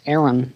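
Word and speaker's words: Aeryn: As the series progresses, John makes enemies with brutal peacekeeper Scorpius while developing a relationship with crewmate Arian. As in the nation? Did I pronounce that right? – I always thought it was just Aaron.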